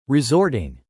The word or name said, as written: resorting